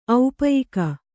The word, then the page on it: ಔಪಯಿಕ: kannada